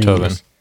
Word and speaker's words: Beethoven: Beethoven.